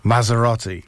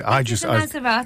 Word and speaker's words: Maserati: It's a Maserati. – Thank you, but it's a Gibby. – Oh, I don't know about that. – It's a Maserati.